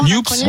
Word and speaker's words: NUPES: Vous le prononcez comment? – NUPES. Moi, je l'appelle NUPES.